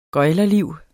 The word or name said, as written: gøglerliv